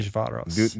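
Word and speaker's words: Dunaújváros: I'm sure I'm saying that wrong. No, that's a great pronunciation. – Dunajvaros.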